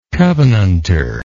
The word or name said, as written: covenanter